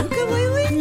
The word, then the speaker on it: ukulele: No, it's pronounced That's the correct pronunciation, is ukulele. – Yeah. – If you pronounce it ukulele, you ain't local, brudda.